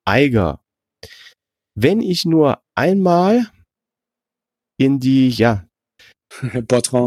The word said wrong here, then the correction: Botrange: Botrange wird ausgesprochen, okay.